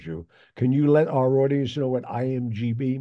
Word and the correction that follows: IMDb: means? – IMDb. – IMDb, sorry. I knew I was saying it wrong.